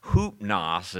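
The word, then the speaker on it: hoopnos (ὕπνος): But then you have a third word, which is an odder word, hoopnos, it's pronounced, but it only appears five times in the New Testament, and it has to do with very deep sleep.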